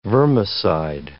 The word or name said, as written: vermicide